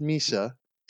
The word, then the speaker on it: Mesa: And there's a little town of Mesa, which looks like Mesa, but is pronounced Mesa.